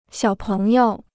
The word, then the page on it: 小朋友: xiǎo péng yǒu